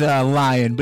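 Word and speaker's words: Lyin: My last name is Lion, but it's spelled differently. – Wait a minute. – Yeah, it's with a Y. L-Y-I-N.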